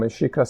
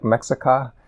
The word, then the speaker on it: Mexica: And Aztec, I guess, is a European term for the Mexica people, when Mexica spelled like Mexica, but it's pronounced Mexica.